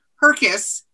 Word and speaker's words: Herkes: Herkes.